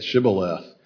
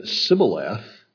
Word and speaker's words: Shibboleth: That's where that word comes from, of course, a test, to do it the right way or say it the right way, the Shibboleth that they applied. And if they said Sibboleth, they died.